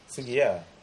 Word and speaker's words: Sugija: Sugiya. Oh, bukan Sugija. – Sugiya. – Oh, ejaan dia J tapi pronunciation dia Ija. What Dutch? – Dutch. Oh, okay.